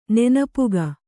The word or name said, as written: ನೆನಪುಗ